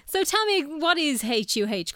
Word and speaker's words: Her: Am I saying that right? – I usually say HUH now. – Okay. – And a lot of our customers do too, so fair.